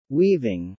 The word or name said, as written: weaving